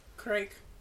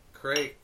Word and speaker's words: Kriek: Crake. – Crake. – Crake. – Well, all right. Do you know how to pronounce it? It's no, you nailed it. It's kind of like creek, I think. It's I don't know. – K-R-I-E-K.